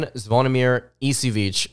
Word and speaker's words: Zvonimir Ivisic: And then you've got Aaron Bradshaw and Ugonna Nyenzo and Zvonimir Ivisic. Again, that's how I believe how you pronounce his name.